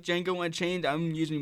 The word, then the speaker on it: Django: I say Django, like the D is silent. – I know the D is silent. It's a joke in the movie. – It's actually Django.